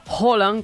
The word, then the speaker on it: Haaland: El grandulón Erling Haaland, o Haaland, como nos dijeron que había que pronunciarlo,